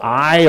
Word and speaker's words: Ai: There's this little settlement called Ai, or Ai, depending on how you want to pronounce it.